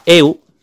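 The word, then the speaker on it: eu (εὖ): La primera derivación es la 20-95, que es un adverbio que se pronuncia eu y que significa bueno.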